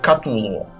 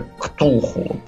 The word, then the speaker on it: Ктулху: А западные, например, произносят это как Катулу. – Да, да, Хотя Катулу. Угу. Лавкрафт, например, оставлял э, записи, что он должен вот именно так звучать как-то потусторонне, именно как какой то Ктулху, такой вот.